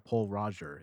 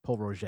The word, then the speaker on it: Pol Roger: Same with, like, people say, oh, I'm going to pick up Paul Roger. It's Paul Roger.